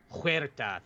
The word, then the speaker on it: Huerta: Huerta, thank you there, because it's, yes, that's the sound the H makes, will indeed be playing Namor in Wakanda Forever.